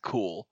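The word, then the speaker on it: Q'ulle: I think I asked my roommate how to say their name, and he said cool.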